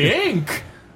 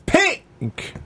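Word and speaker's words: P!nk: How do we pronounce that? I mean, we can't just Pink. – No, it's Pink.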